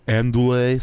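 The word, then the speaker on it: andouille: What is it? – Andouillet sausage. – Oh, andouillet. – Sausage. – Andouillet. Yeah. – Scrambled egg. – Andouillet sausage. That's how you say it.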